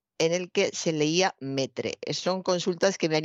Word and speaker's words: metre: Pues sí, así como suena, metre, M-E-T-R-E. E, es como se ha adaptado al español la palabra METRE, la palabra francesa, que se escribe maitre, se ha adaptado metre al español.